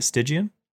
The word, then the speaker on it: Stygian: The Stormbind one, they came out with a whole whack load of Stygian. I swear English is my second language. Is that how you say it? Stygian? – Yeah.